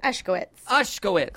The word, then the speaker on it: Ushkowitz: Did I say that right? – Ushkowitz. Close. I mean, it's close enough.